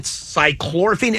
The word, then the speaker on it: cyclorphine: They said that whether it's fentanyl, whether it's cyclorphine. Am I saying that right? Do you know? – Yeah. You're saying it right.